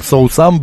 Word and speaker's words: соусам: Именно с таким ударением, соусам, блюдам. Да.